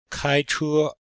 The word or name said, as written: kai1che1